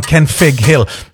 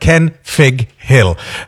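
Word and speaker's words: Kenfig Hill: Are people saying it wrong? Ken Fig Hill. Nope, that's absolutely right. Ken Fig Hill with an F. Because the original Welsh is Mynydd Cynfig, which is a double F.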